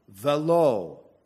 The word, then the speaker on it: velo: This word here is pronounced velo, and not. Or the word is pronounced velo, and it means and to him.